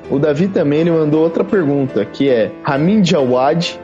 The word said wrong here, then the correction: Ramin Djawadi: Ramin Djawadi.